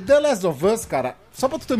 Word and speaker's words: of us: Rapidinho, no off, no off, no Last of Us. É óbvio. É off. É, então, é só juntar, of us. Aí fica of facinho, cara.